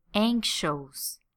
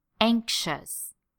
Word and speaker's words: anxious: Here in Brazil, it is very common to hear people pronounce words like famous and anxious like this: famous and anxious. It is a very common mistake. So I'm going to tell you exactly how to pronounce these words. Here we go. Famous and anxious. Not famous and anxious. These sound like Portuguese. Famous.